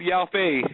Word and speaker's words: Yao Fei: It's so cool that they said Yao Fei in this episode. That means I wasn't pronouncing his name wrong.